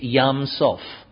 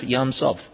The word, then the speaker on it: Yam Suf: If I pronounce its Hebrew name, it's Yam Sof. So Y-A-M-S-U-F, Yam Sof.